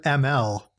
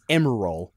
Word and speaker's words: Emerl: No, they in the English dub they pronounce it ML. – I've always said it in my head, and I guess not that many times out loud, as Emerald.